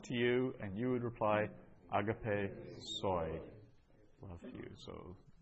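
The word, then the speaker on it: agape (ἀγάπη): It's agape. The accent's on the second syllable, so agape.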